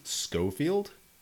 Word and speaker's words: Schofield: So Glenn Schofield, yeah, is that how you say it? – Yep.